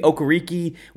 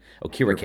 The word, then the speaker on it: Okereke: His pronunciation was used to be Okereke when he was in college. And then, you know, he's one of those guys that like once he got to the NFL, he finally said, nah, that's not how you pronounce my name.